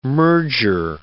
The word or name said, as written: merger